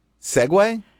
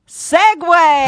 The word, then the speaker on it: segue: Segue? – Okay, it's spelled S-E-G-U-E. – Uh-huh. – The mispronunciation is... – Segue? – Seg. – Uh-huh. – Versus Segway.